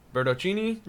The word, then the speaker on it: Bertocchini: Bercini. – Bertocchini, um, who's an amateur.